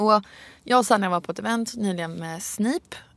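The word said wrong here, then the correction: Sniph: Sniff, ursäkta. Sniff. Sniff. Det stavas Snip och ett H på slutet. Men det uttalas ju Sniff.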